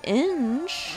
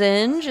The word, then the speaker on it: Inge: All right, so up next is James Inge. – Right. – Am I saying that right? – Yeah. – Yeah. – Uh-huh, like an engine. – Get in here, right? James Inge.